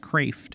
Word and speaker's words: Kreeft: Now, wisdom, in a Pascalian sense, wisdom in a Kraft sense. He has that Dutch name. It's spelled like Kreeft, but it's pronounced Kraft.